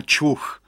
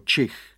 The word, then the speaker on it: čich: Ale pes Kvik má za ušima a co hlavně má čuch, který by se správně měl vyslovit jako čich.